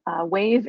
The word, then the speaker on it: WAEV: So earlier this week, EV startup Wave Inc., and that's spelled W-A-E-V, acquired